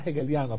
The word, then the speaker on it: hegeliano: Se está basada en ese error hegeliano, se pronuncia hegeliano a pesar de ser una H. Es uno de los únicos, en la lengua española, es una de las pocas excepciones que se hacen con la H, porque la H en el español es silencioso, por aquí suena, que tiene sonido.